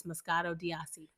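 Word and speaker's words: Moscato d'Asti: If for all of our listeners who know how to pronounce things, it's Moscato d'Asi.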